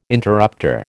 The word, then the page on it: interrupter: American English